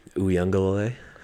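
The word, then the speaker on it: Uiagalelei: I want to hear you pronounce his last name. – DJ Uyunglele. – Yeah, there you go. – That was pretty good. – Better than I expected.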